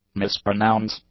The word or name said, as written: mispronounce